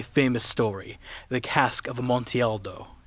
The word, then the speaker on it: Amontillado: It's the cask of Amontillado.